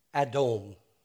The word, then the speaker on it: Adom: It sounds like the Hebrew word for red, which is Adom. Edom, Adom. It also sounds like the Hebrew word for man, Adam.